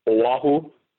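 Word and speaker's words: O-Ahu (Oʻahu): So, for example, for the word Oahu, which is our fourth largest main island, it's spelled O-A-H-U. And the proper way to spell it is O with the okina and then an A-H-U. So that's sort of like a pause. It's actually pronounced O-Ahu. And so the okina is just used to put a little pause there for the pronunciation.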